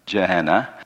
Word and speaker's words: Gehenna: It's actually pronounced Gehenna, but it's G-E-H-E-N-N-A.